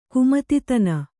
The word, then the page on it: ಕುಮತಿತನ: kannada